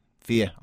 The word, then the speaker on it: Fiadh: It's Fia. – Yeah. Fia. I know a Fia. – Yeah. F-I-A-D-H.